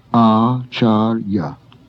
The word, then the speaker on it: acharya: The next word has two long A's and a final short A, acharya, teacher.